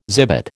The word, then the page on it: zibet: American English